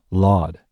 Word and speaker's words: Lord: After another brief pause, Doom punctuates the current rhyme scheme with Lord save us, pronouncing Lord in the colloquial Laud and stretching it into 2 syllables so the phrase fits the 4-syllable scheme.